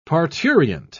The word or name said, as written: parturient